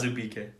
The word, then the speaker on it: Azubuike: It's Azubuike, but it's okay.